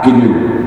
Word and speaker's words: GNU: El sistema que desarrolló lancé, comencé en 84 el desarrollo, se llama GNU, G-N-U, se escribe, se pronuncia en inglés GNU.